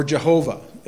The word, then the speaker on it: Jehovah: Yahweh, or Jehovah, as we might pronounce it as well.